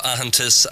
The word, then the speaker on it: Aitheantas: Well, founder of AHANTIS and co-author of that report that we mentioned, which is available to view on the AHANTIS website, and that's spelled AITHEANTAS.